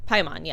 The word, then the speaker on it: Paimon: That's Palmon. – Paimon, yeah. Paimon, or yeah. Palmon. Oh, Sorry, yeah.